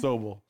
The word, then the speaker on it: Sobel: Sobel.